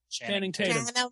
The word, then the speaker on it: Channing Tatum: Channing Tatum.